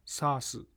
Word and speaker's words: SaaS: クラウドサービスは大きく分けて SaaS、 PaaS、 IaaS の 3 つの種類があります。それぞれ順に説明していきます。 SaaS はソフトウェア・アザ・サービスの略で SaaS と発音します。